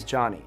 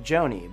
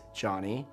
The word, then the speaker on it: Jony: For example, my name is Johnny, spelled J O N Y. And it's normal for someone to pronounce my name like Joni,